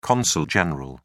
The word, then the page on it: consul general: British English